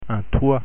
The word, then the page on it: toit: French